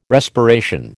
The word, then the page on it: respiration: American English